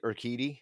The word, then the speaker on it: Urquidy: But yeah, the Urquidy would have been, is that how you say it? Urquidy? – Yeah.